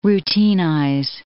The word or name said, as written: routinize